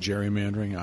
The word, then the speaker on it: gerrymandering: Um, and and you're going to see you, you still see a lot of that with the I call it gerrymandering. It's actually everybody else pronounces it gerrymandering. I'm I'm sorry, I'm a snob. A hard G. It has to be a hard.